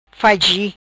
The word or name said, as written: Faichi